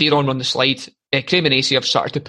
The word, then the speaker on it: Cremonese: I keep saying it wrong. It's not Cremonese, it's Cremonese.